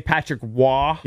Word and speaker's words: Patrick Roy: It's spelled ROY, but you say Patrick Roy.